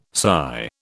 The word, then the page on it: sigh: British English